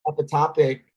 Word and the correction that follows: ectopic: I always say it wrong. Epitopic, is that it? – Ectopic, yeah.